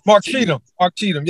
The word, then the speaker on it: Mark Cheatham: Mark Cheatham.